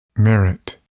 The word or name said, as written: merit